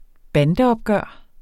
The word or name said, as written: bandeopgør